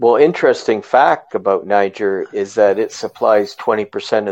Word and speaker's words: Niger: So speaking of future wars and new conflicts developing, we have the country of Niger that was given an ultimatum to reinstate their president. – Is that how that's pronounced? Uh-oh. – Yes, it is. I know you've been getting it wrong.